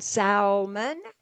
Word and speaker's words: salmon: You might as well go ahead and pronounce the L in Salmon.